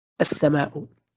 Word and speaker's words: السماء: مثلا كلمة السماء تقرأ هكذا، السماء، بمعنى بحذف ال في النطق.